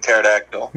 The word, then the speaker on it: pterodactyl: What if you do testosterone that starts with a silent P, like pterodactyl? – No, it still doesn't work.